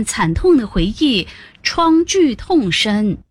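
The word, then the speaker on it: 创巨痛深: 你想想，有幸活下来的人回想那段历史，创剧痛深，永远也忘不了。 – 不是创剧痛深，得读作第一声创，创剧痛深。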